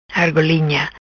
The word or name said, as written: Argolinha